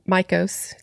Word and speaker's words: Mikos: This is the story of Dr. Ronald Mikos, spelled M I K O S M I K O S. I that's how I was pronouncing it.